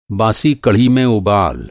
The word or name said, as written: बासी